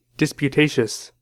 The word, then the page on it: disputatious: American English